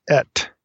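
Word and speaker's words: et (את): It's pronounced et, so it's aleph tav, et.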